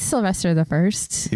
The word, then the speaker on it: Sylvester I: I think it's Sylvester the First. – Even though it's not like a genetic dynasty?